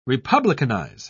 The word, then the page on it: republicanize: British English